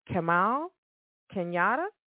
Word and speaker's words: Kamau Kenyatta: Now, this album is an arousing debut by one soulful singer together with Kamal Kenyatta. Did I pronounce that correctly? – Yes, that's correct.